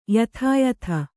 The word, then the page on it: ಯಥಾಯಥ: yathāyatha